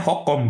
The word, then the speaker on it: H&M: Mm -mm, A itu cenderung dibaca O kalau di sono. Termasuk H&M. H&M kan merek Swedia tuh. Bacanya Hokom, Pak.